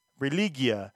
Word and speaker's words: Religia: Religia. – Religia. There you go. Better pronunciation.